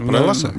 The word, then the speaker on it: меласа: Я правильно ударение поставил? – Да, меласа. Меласа.